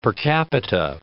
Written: per capita

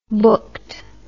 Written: booked

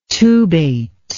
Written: tubate

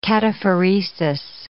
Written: cataphoresis

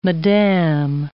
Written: madame

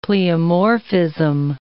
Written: pleomorphism

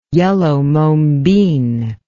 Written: yellow mombin

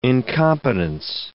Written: incompetence

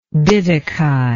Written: diddikai

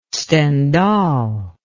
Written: Stendhal

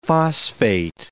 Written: phosphate

